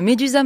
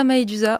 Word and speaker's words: Maedusa: Vous le prononcez, euh, ça s'écrit mais euh, on peut prononcer les deux, Médusa, Maédusa. Moi, je dis Maédusa, mais euh, aucun problème pour Médusa.